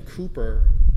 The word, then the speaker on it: Cowper: William Cooper, spelled Cowper, but it's pronounced Cooper, was a hymn writer.